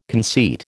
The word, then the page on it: conceit: American English